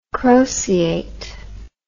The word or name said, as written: croceate